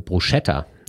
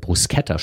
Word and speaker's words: Bruschetta: Wer also von Bruschetta anstelle von Bruschetta spricht, könnte ein Problem bekommen.